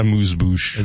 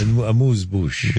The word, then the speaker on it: amuse-bouche: Amuse-bouche. – Amuse-bouche.